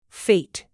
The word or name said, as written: fete